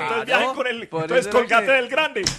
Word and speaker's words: Colgate: Bueno, no se dice Colgate, se dice Colgate.